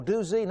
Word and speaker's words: doozy: If you're in any city where you see Another Life, CBN soap opera, November 6th, boy, it's a doozer. I mean, it really will grab you. – Well, the correct pronunciation is doozy. – Oh, doozy, not doozer? – Yes, doozy.